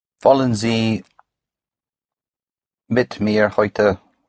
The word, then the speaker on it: Heute: Heute. – Heute. – Heute is spelled H-E-U-T-E. The E-U always has the sound of an oi.